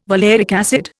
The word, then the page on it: valeric acid: Indian